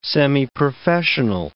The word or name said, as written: semiprofessional